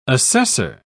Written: assessor

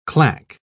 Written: claque

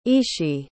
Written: ishi

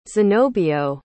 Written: zenobio

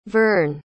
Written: verne

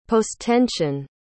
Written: posttension

